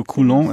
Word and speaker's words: Coulomb: Kaj la transferita ŝargo ĉe la fulmoj estas 10,000 kolumb, kai tio... – Coulomb, yes. – Kiel oni legas tion? – Do, mi konas la francan pronuncon Coulomb, estas tio. – Do, mi legus Coulomb.